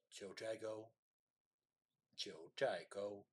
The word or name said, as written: Jiuzhaigou